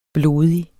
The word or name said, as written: blodig